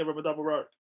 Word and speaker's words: Ramadan Mubarak: Ramadan Mubarak. – Hey, man. Thank you, sir. – Did I say it right? – Fasting. What? – Did I say it right? – Yeah, Ramadan Mubarak.